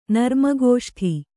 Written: ನರ್ಮಗೋಷ್ಠಿ

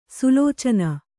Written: ಸುಲೋಚನ

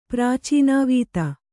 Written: ಪ್ರಾಚೀನಾವೀತಿ

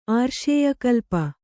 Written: ಆರ್ಷೇಯಕಲ್ಪ

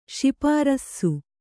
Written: ಶಿಪಾರಸ್ಸು

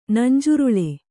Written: ನಂಜುರುಳೆ

